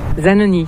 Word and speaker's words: Zanoni: Quand tu prononces ton nom de famille, tu le prononces avec quel accent? – Alors moi, je le prononce à la française. – Zanoni.